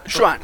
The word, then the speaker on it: Xuan: Se llama Juan como Juan, pero con X, y se pronuncia como CH, pero como con... Xuan. Juan Listo.